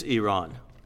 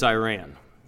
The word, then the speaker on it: Iran: If you're from the country, it's Iran. If you're from America, it's Iran.